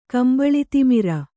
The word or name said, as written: ಕಂಬಳಿತಿಮಿರ